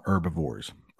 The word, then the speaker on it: herbivore: The H is silent. So herbivores eat only vegetables. If you go to the southern part of the United States, they actually might they might pronounce that H, herbivore. But we call them herbivores.